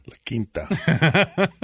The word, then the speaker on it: La Quinta: And, you know, eating at the local Wendy's, staying at the local La Quinta. Is that how you say it? – La Quinta.